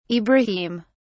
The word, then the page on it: ibrahim: British English